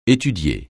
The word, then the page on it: étudier: français